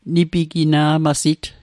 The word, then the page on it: nipigina'masit: Mi'gmaq